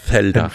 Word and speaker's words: Zelda: Zelda. – Fünf. – So vom so ist Z wie Zelteln, oder? So spricht man das aus.